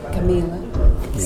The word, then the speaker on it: Camilla: How do you pronounce it? I always get it wrong. – Camila. C-A-M-I-L-L-A.